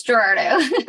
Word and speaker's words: Ghirardo: Pretty close. Ghirardo.